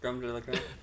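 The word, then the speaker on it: crème de la crème: How's my pronunciation on that? – Yeah, it's fine. – Creme de la creme.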